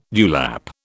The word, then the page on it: dewlap: British English